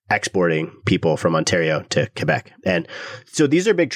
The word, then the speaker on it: Quebec: And actually, I think 2021 was actually the first year where Quebec, a lot of listeners have been getting upset with me for not pronouncing it properly. So they go, Quebec, I'm going to make an effort to do that properly because it does matter.